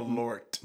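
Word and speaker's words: Malört: You're going to have some more Malloy. – Wait, did I say that right? – Malort.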